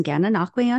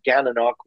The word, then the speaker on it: Gananoque: He lives with his spouse in Gananoque, Ontario, Canada, where he enjoys camping and boating in the Thousand Islands.